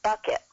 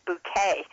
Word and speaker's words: Bucket: And their last name is Bucket, B-U-C-K-E-T, just like a pail, and she keeps trying to get people to pronounce it Bouquet. Miss Bouquet. And poor husband looks at her and says, but it's always been Bucket in my family.